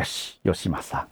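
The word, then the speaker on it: 芳正: 方念 Yoshi，正念 Masa，还有 Yoshi Masa。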